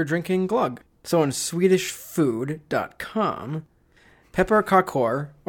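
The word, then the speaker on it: pepparkakor: We're going to have some pepperkakor cookies, which is pepperkakor. Pepper, how do you say it? – Pepperkakor.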